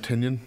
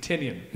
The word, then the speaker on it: Tynion: That was James Tyrion, who wrote that one. – James Tynion? – Tynion, that's it. I'm sorry, I knew I was saying his name wrong.